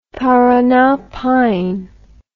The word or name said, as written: parana pine